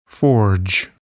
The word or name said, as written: forge